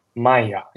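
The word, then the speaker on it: Maia: Maya 应该叫应该应该这样发音，就是 M I A I，好像是一个星球的 M I A I，嗯。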